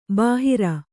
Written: ಬೇಹರ

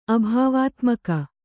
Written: ಅಭಾವಾತ್ಮಕ

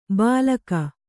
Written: ಬಾಲಕ

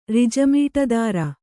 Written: ರಿಜಮೀಟದಾರ

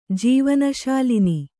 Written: ಜೀವನಶಾಲಿನಿ

